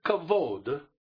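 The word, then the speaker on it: kavod (כבוד): It's the Hebrew word kavod. – 希伯来文 kavod. – And kavod, K-A-B-O-D, it's spelled. – K-A-B-O-D.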